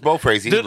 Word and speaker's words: Beaufraase: Pronounced Bo Frazzi, just like the Prince song, Go Crazy.